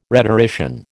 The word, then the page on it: rhetorician: American English